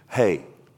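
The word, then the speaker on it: Hey (ה): Okay, here's how you pronounce that in Hebrew. Hey.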